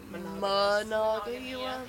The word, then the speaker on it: Monongahela: Did not know how to say it until I always it's like M-O, so I'm like but it's like Monongahela. – Yeah. – I was saying it wrong the whole time.